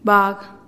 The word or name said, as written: bag